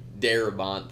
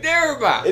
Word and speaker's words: Darabont: Yeah, you don't want to do the John Bernthal, Frank Darabont thing. – Frank Darabont. – And then I don't think they said that right. They were like Frank Darabo or something, Darabno or something.